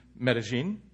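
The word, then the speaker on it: Medellín: First time I said it correctly. You know why? Because I had supper with our other partners in Medellin, and she said, just say it like your genes, you know?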